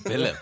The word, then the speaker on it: Willem: Yeah, in South Africa, we'd pronounce this as For Willem. – Yeah, For Willem.